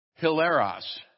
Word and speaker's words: hilaros: The Greek word that's translated cheerful is hilaros. We would spell it in English, don't speak it much, H-I-L-A-R-O-S, hilaros.